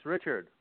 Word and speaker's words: Richard: Yes, it's Richard.